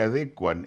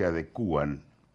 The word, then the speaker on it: adecuan: que las cosas se adecuan y no se adecúan,